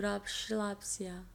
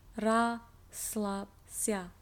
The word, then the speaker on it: Расслабься: Я буду расслабься. – Расслабься. – I said it wrong. Расслабься. – Расслабься. – There's no sh. – Расслабься.